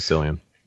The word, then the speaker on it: Cillian: But if you haven't seen this movie, it stars Rachel McAdams and Cillian Murphy, or Killian, however you want to pronounce his first name. I still am not certain, but Cillian? Okay. – It is Cillian.